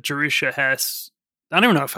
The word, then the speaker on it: Jerusha: It looks like Joshua, but Jerusha.